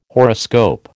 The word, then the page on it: horoscope: American English